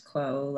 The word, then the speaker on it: Kuo: Hi, my name is Phoebe Kuo, pronounced Kuo like status quo, but with a K.